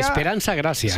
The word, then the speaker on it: Esperanza Gracia: Esperanza Gracia.